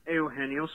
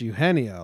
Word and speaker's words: Eugenio: I think it's Eugenio. Like, no, the G is an H.